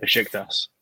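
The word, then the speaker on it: Beşiktaş: I mean, just like when Dele Alli went to Besiktas, I don't know how to say it. – Besiktas.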